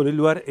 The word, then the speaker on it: Leloir: El móvil Leluar es el 341 4 44 institutoleluar.edu.ar Escribimos a secretaría arroba instituto Leluar se escribe Leloir con I latina.